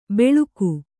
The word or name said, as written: ಬೆಳುಕು